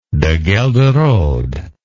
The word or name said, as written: deghelderode